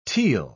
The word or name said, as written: teal